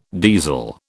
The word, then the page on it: diesel: British English